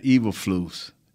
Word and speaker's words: Eberflus: More importantly, it's Matt Eberflus. Is I'm pronouncing his name right? – Yeah, you got it right.